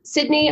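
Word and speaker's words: Cydney: So it's Sydney underscore Ross, but Sydney is spelled to C, C-Y-D-N-E-Y.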